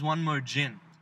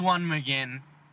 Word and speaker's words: One Mo'Gin: and he laughed at me and said the song is One More Gin. I listened to the song closely. It is One Mo'Gin because it rhymes with again. It's not gin. He ain't talking about booze.